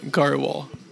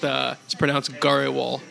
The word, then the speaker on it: Garhwal: It's just the way I guess it's spelled. Yeah, it should sound like a Grewall, but it's pronounced Garhwal.